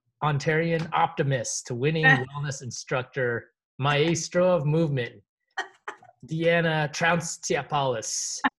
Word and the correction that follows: Dina: Close. It's Dina, but good enough. – Dina.